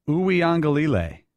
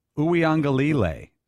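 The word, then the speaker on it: Uiagalelei: I found a Clemson site that has a phonetic spelling pronunciation. McLovin's really close. He may have it nailed. Uyangalele. Uyangalele, according to a Clemson site.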